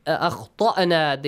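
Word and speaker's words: أخطأنا: But akhtana, the ta has to be pronounced heavily. It's a heavy sound.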